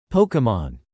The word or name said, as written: Pokemon